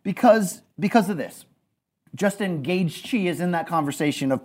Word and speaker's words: Gaethje: He hates my Gaethje pronunciation. – Okay. – Gaethje. G-A-G-E-Y.